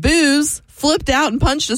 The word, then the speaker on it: Booze: Like spelled like booze. – B-O-O-Z-E. And just like booze, you spend the night with her and you wake up feeling both regret and nausea.